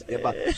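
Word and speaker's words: Mbappé: And you saw, where Mbappe wins the Golden Boot. – Is the E silent, or do you pronounce the E? Is it I like Mbappe. I like Mbappe. It's Mbappe.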